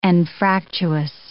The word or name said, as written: anfractuous